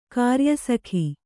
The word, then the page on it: ಕಾರ್ಯಸಖ: kāryasakha